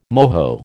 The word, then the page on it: moho: American English